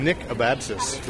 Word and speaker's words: Nick Abadzis: I'm here at Comic-Con at the First Second Books and Macmillan Publishing booth with Nick Abatsis, who, did I say it right? – You did, yes.